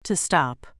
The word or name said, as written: to stop